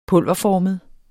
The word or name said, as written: pulverformet